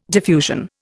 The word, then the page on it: diffusion: Indian